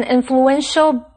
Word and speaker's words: influential: Wesley 得到了普林斯顿大学的奖学金。继续我们看的是 influential 这个形容词，注意它的重音是在 en 这个音节，念成 influential。influential 是指具有影响力的或者是有权势的。例如 ,Eric is an influential biochemist. Eric 是一位举足轻重的生物科技学家。他的动词是 influence, I-N-F-L-U-E-N-C-E, 意思是影响。注意这时候重音在第一个音节，念成 influence。例如 ,Brenda is always influenced by other people's comments.